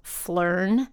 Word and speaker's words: Phlearn: His name is Aaron Nace, by the way, and his YouTube channel is called Phlearn.